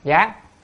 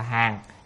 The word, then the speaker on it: hàng: nhưng mà lúc thì đọc hàng, lúc là đọc gián, ở đây phải đọc là hàng.